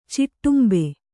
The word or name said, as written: ಚಿಟ್ಟುಂಬೆ